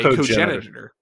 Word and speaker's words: cogenitor: who is referred to just merely as a cognitor, as a cogenitor.